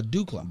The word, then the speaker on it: DuClaw: No, this is the first D-Claw. – It's first D-Claw. – Okay, cool. I thought we did something. Okay. – Why are we saying D-Claw? Duclaw.